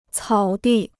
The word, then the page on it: 草地: cǎo dì